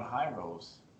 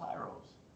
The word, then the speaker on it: Hirose: Going Hirose. Spelled Hirose. Is that Hirose? – I think that's how you pronounce it.